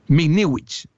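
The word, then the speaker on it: Michniewicz: Mikniewicz,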